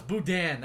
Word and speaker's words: Boudin: Oh, it's Boudin. – Look at that one. No, it's Boudin. That's how you pronounce it, Boudin.